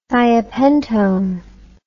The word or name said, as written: thiopentone